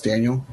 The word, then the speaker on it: Daniil: Yeah, so it's spelled weird, but just pronounced Daniel.